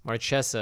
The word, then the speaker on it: Marchesa: In fact, my retooled Marquesa deck, although Marchesa is what the Wizards pronunciation guide says.